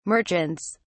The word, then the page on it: merchants: British English